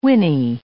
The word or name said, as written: whinny